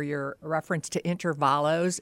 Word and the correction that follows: Intervalli: It's Intervalli.